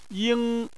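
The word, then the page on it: ying: tone 1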